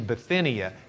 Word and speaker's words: Bithynia: I left out the H. It's not Bithynia. See, if the preacher came in here and he heard me correct his pronunciation of Kairos, he'd be saying, well, at least I put my H in Bithynia, and he'd be right, so we'd be even.